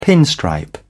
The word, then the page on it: pinstripe: British English